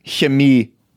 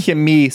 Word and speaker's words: Chemie: Chemie. – Chemie? – Chemie. C-H-E-M-I-E.